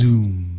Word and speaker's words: Xoom: It's pronounced Xoom. – It's not pronounced Xoom. – Yes, it is. – Really? – Yeah, I don't know. That's the way it's spelled. – Okay. That's like saying... – Yes, it is, maybe. – That's like saying xylophone.